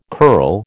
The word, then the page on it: curl: American English